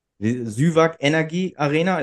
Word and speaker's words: Süwag Energie Arena: Ähm, Süwag Energy Arena, ist das richtig ausgesprochen? – Absolut.